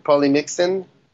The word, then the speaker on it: polymyxin: We are using nemocillin or polymyxin.